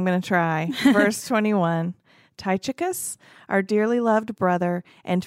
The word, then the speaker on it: Tychicus: But there's another C. – Tychius? – Oh, you're right.